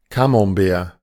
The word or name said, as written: Camembert